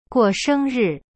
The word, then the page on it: 过生日: guo sheng ri